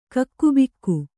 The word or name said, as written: ಕಕ್ಕುಬಿಕ್ಕು